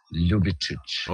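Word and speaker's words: Ljubičić: Und dieses LJ ist eigentlich ein Buchstabe in unseren Sprachen und wird wie L ausgesprochen. – Okay. – Und deswegen heißt der Junge Ljubicic.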